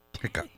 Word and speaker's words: Hickok: That's Hitchcock. – That's right. Hickok. Silent H. Silent T-H.